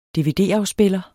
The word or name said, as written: dvd